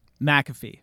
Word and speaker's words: McAfee: I got the first one is Laces Out with Pat McAfee. McAfee? I always say it wrong with my accent. McAfee. – McAfee.